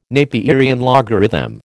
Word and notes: Napierian logarithm: American English

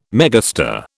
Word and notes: megastar: British English

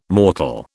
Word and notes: mortal: British English

